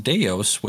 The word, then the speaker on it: Devs: It's spelled D-E-V-S. Apparently, they mention later on that the V is actually a U, so it's pronounced Deus, which is, smash that up to Ex Machina, and it fulfills that whole movie.